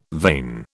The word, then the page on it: vein: British English